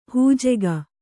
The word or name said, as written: ಹಿಜಿಗು